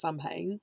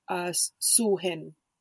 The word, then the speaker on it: Samhain: But so on this day, we're looking at something, a day called Samhain, which actually, it's not pronounced Samhain, it's pronounced Sulhin.